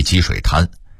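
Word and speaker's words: 积水潭: 北京有个地名叫积水滩，潭的读音是二声潭，但北京人一般都念一声滩，即积水滩，好像那地方当年是积水形成的沙滩似的。其实积水滩在元代时水面相当大，一直到它现在在北面的北师大校区都是一片汪洋。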